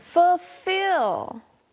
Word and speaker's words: fulfill: Next, we have fulfill. Even though there's an L there, we usually don't pronounce it.